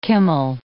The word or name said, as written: kummel